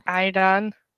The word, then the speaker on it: ayran: How do you say that? I- ayran. Ayran. It's like a yogurt drink.